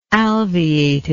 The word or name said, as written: alveated